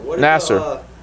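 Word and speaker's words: Nassar: Is that how you say it? Nasser? – Larry Nasser.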